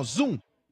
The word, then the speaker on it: Zuum: Se pronuncia assim, ó, Zoom, e se soletra da seguinte forma. Z-U-U-M, tá bom? Z-U-U-M. M de Maria no final.